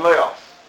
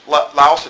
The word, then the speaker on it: Laos: Well, the term Nicholas comes from two Greek words, Nikos and Laos. Okay? Which, Laos, if you think of the country Laos, it's spelled exactly like that, L-A-O-S.